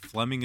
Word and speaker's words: Flemyng: It is Jason Fleming, but Fleming is spelled F-L-E-M-Y-N-G.